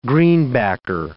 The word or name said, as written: greenbacker